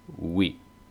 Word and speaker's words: oui: Recuerden que la OU se pronuncia U y luego la I, entonces oui.